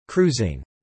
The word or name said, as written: cruising